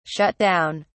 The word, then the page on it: shut down: British English